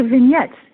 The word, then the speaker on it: vignette: Now, when you say eighth Vignette, I'm not pronouncing that right. What does that mean? – Could you say that again? What was the question? – The eighth Vignette. – Oh, the vignettes.